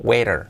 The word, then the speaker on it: waiter: Waiter, escrito con la T como resuave, waiter, waiter.